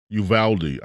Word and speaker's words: Uvalde: Uvalde, I believe is what how it's pronounced. – Yeah, that's right.